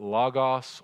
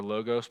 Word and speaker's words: Logos: By the way, you can pronounce it Logos or Logos, but that's the Greek word for word.